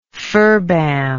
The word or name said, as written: ferbam